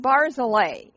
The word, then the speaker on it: Barzillai: Now, the name Barzillai, I'm not positive I'm pronouncing it 100% correct, but it's spelled that way, and it reminds me of like Swiss Chalet, a Swiss Chalet and Barzillai.